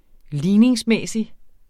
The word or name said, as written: ligningsmæssig